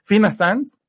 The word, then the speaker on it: Fina Sanz: Tiene formación, imagínense con quién, con la Master de Masters, Fina Sanz. Sí, ¿verdad? si lo pronuncié bien. Sí, sí, sí, sí.